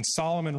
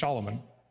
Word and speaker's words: Solomon: When Solomon reigns, Solomon's name literally means shalom man. We pronounce it Solomon, but it's Shalomon.